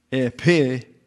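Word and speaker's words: epignosis: Epinosis. Epinosis, therefore, the G is not pronounced, by the way, here. And therefore, the pronunciation of this word here is epinosis.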